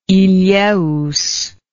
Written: ilheus

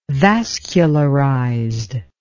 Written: vascularized

